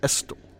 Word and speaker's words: Estill: Looked it up, had a South Carolinian confirm the pronunciation. Estill in Hampton County.